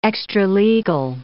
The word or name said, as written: extralegal